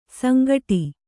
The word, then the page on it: ಸಂಕಟು: sankaṭu; Kannada